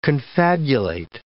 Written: confabulate

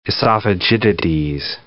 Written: esophagitides

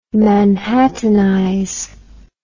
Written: manhattanize

